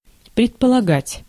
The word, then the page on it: imaginer: France; /i.ma.ʒi.ne/